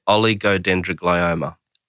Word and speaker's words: oligodendroglioma: I'll give you an oligodendroglioma.